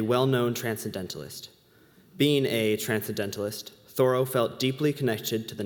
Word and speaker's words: Thoreau: Disclaimer, Thoreau is how you pronounce his last name back in the day.